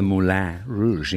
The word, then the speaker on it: Moulin Rouge: My adopted rainbow province name is Serenity Safe Harbour, yet in a past life, I was a colourful identity at the Moulin, that's how you say it, at the Moulin Rouge in Gay Paris.